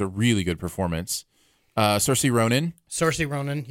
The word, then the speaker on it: Saoirse: So she's like, I just tell people it's like Saoirse, like inertia.